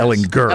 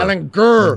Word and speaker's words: Ehlinger: pronounce Sam's name with the soft G, not the hard G. It really got annoying. – Brutal. – Yeah, we need to make sure that everybody knows. Yes. Ehlinger. – Ehlinger. – Ehlinger. Get your grr.